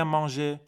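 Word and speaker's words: manger: چرا میگم مانژه چون وقتی به صورت انفینیتیو میاد بعد او آخرش تلفظ بشه اقش رو تلفظ نمیکنی ولی او آخرش تلفظ میکنی پس این وقتی صرف میکنم میگم جو مانژ ولی وقتی قرار انفینیتیفش بیاد میگم مانژه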